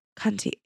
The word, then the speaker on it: Contigo: Contigo. Yeah. – How do you spell it? – C-O-N-T-I-G-O.